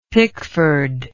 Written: pickford

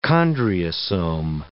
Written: chondriosome

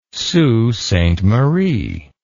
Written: Sault Ste. Marie

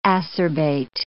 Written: acerbate

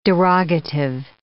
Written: derogative

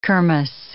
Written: kermesse

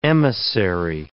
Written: emissary

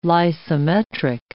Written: lysimetric

